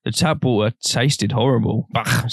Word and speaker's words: Baugh: That's how it's spelled, bro. Yeah. B-A-U-G-H. Bach.